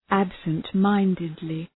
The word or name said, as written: absent-mindedly